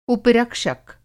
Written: उपरक्षक

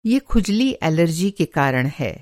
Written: खुजली

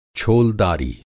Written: छोलदारी

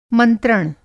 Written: मंत्रण

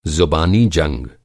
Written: जंग

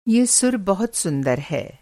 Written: सुर

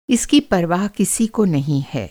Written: परवाह

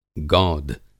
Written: गौद

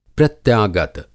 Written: प्रत्यागत

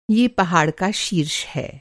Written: शीर्ष

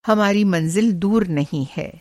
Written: मंज़िल

